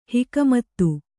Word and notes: ಹುಕುಮತ್ತು: hukumattu

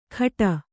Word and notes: ಖಟ: khaṭa

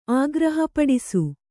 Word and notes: ಆಗ್ರಹಪಡಿಸು: kannada

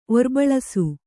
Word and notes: ಒರ್ಬಳಸು: Kannada

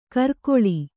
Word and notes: ಕರ್ಕೊಳಿ: kannada

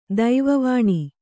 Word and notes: ದೈವವಾಣಿ: daiva vāṇi

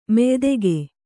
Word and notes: ಮೆಯ್ದೆಗೆ: meydege

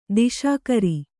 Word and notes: ದಿಶಾಕರಿ: diśakari

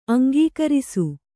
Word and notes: ಅಂಗೀಕರಿಸು: aŋgīkarisu

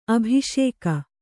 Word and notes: ಅಭಿಷೇಕ: abhiṣēka

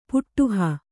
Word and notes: ಪುಡೆಹ: Kannada

